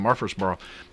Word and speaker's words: Murfreesboro: some priorities for the city of Murfreesboro, and one of the things was to develop the city brand.